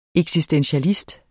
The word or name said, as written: eksistentialist